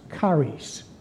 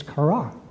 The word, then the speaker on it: charis: charis. The New Testament word for joy is chara.